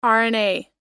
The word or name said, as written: rna